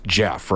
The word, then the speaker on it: Geoff: So G-E-O-F-F is pronounced Jeff, right? – Yeah.